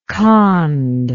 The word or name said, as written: Khond